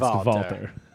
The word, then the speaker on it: Walter: But he put it down as Paul Walter, but then he was like, no, it's German. It's pronounced Valter.